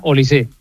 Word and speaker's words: Olise: No, es francés, no lleva acento, pero se pronuncia Olise.